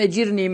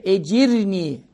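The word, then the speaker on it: ecirni (أجرني): Allahümme ecirni. R'le. Rize'nin R'si. Ecirni minen nâr.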